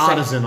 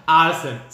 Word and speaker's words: artisan: Tidal come in a citrus, a lime, and an artesian seltzer, which for mine, the name artesian seltzer almost writes it off. But yeah, so artisan, I believe, is the pronunciation of that word. – Isn't it artesian? – No. – It's artisan. Artisan.